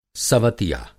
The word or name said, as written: सवतिया